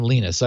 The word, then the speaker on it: Linus: So on last Thursday, he does pronounce his name Linus. I always feel self-conscious saying Linus. Yes. – Everybody's saying it's Linus.